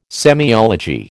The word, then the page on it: semiology: American English